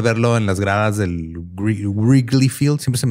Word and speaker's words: Wrigley: Wrigley, como Wriggle Wriggle.